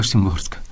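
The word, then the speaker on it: Szymborska: Yo había leído muy poco de, igual lo pronuncio mal, eh, Wisława eh, Simborska. – Wisława, Simborska.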